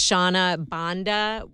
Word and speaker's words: Shona Banda: So again, her name is Shauna Banda, we're pronouncing it, S-H-O-N-A-B-A-N-D-A.